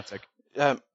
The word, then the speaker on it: Niantic: Just to end, obviously there's been petitions wanting is it Niantic? I can't remember how you pronounce it. – Yeah, Niantic.